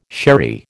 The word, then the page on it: sherry: American English